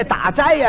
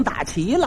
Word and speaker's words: 斋: 他也错了一个，这打斋呀，他给念成打旗了，斋字他念成旗了。